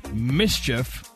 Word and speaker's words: MSCHF: All right, according to the New York Post, there's a Brooklyn artist named Mischief, but it's spelled all caps M-S-C-H-F.